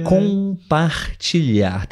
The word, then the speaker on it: compartilhar: Temos um sonzinho nasal na primeira sílaba, né? – Sim. – Com, com, par, fácil, né? Par, ti, também fácil. E eu acho que a sílaba mais difícil é a última, né? É, é o LH. Liar, liar. Compartilhar.